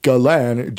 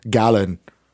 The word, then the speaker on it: Gallan: That's a Galan, G-A-L-L-A-N. Alternatively, you could pronounce it as Galen, but then that sounds like a drink.